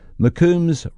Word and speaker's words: McCombs: And Blanche says, P.S., the name was pronounced as McCombs rather than with the long O.